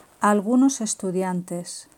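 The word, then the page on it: algunos estudiantes: Spain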